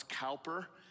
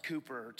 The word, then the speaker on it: Cowper: Cowper is spelled C-O-W, so it sounds Cowper, but it's pronounced Cowper.